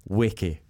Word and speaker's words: Nweke: For a long time, people were calling you Nweke. – Yeah. – But it's Weke. – It's Weke, yeah. The N is silent, very much silent.